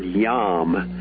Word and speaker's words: yom: The Hebrew word in Genesis 1 is the word yom. And if you were going to spell it with English letters, you'd spell it Y-O-M.